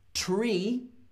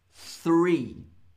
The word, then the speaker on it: three: Pronunciation. Tree. Three. Tree. Three. You know the difference? So, tree or three. T, th. Tree, three. Three trees. Three trees. I hope that makes sense.